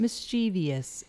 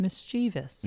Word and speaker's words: mischievous: So there is a tendency to want to put that extra E sound in it. So it's not mischievous, it's mischievous. – Isn't that interesting? – And it's spelled that way, too. – V-O-U-S.